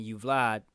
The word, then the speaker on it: Uvalde: Uvalde, how you say it? – Sure.